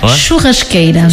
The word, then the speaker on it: churrasqueira: C'est churrasqueira, c'est ça? – Churrasqueira.